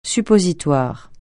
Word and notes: suppositoire: French

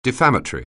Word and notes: defamatory: anglais

